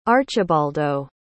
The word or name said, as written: archibaldo